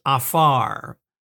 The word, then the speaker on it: aphar: The Hebrew word is afar. In English, you would spell it A-P-H-A-R.